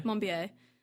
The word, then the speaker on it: Monbiot: Monbiot.